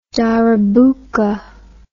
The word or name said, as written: darabuka